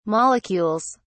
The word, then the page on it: molecules: British English